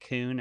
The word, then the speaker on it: Kuhn: And is that Kuhn? Am I pronouncing that correctly? – Yes. Yeah. I always say like raccoon.